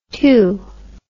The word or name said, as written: tew